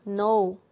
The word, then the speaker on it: know: 자, 발음은 know이지만 뭘로 시작해요? know. 저 K로 시작합니다.